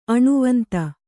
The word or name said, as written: ಅಣುವಂತ